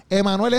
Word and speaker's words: Emanuel: Emanuel es con una M y con una L. Como tú, como tú lo escuchas, y se escribe.